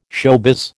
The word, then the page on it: showbiz: American English